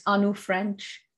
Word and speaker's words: Anu French: It's just Anu French, my name.